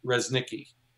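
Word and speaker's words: Reznicki: But my Instagram is Resnicki.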